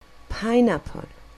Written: pineapple

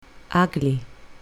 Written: ugly

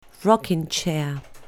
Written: rocking chair

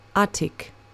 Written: attic

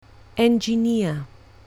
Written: engineer

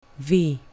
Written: v

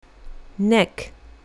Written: neck

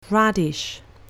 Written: radish

